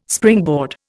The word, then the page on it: springboard: Indian